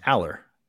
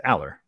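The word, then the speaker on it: Allar: It's Aller. It's Aller. Al. Like the name, like from Step by Step, and then Lur.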